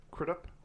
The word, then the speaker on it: Crudup: All right, I went with Billy Crudup. Did I say it right? – Kind of. Crudup, I think.